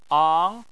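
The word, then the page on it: ang: tone 2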